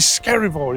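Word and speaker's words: Skerryvore: I've met the main man, the headline act for tonight on the Friday, is Skerivore. Is that how you pronounce it? – That's it, you've nailed it.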